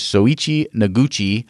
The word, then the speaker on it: Soichi Noguchi: And then the last astronaut, and hopefully I can say this correctly, is Soichi Noguchi,